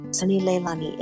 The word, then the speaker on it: Sunni Leilani: Sunni Leilani.